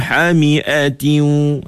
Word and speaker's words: حمئة: Ha, cuba Puan Halimah tu ulang semula, betulkan dekat Hami tu. Jangan panjang Mi. – Tak panjang. – Bacanya wajadaha tagrubu fi ainin hamiatin.